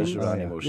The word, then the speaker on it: Jerónimos: I don't know how you pronounce it, Hieronymus. – Oh, the Jerónimos. Yes. Yeah, that's, yeah.